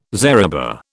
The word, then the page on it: zariba: British English